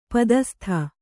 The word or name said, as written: ಪದಸ್ತ